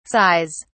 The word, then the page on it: sighs: British English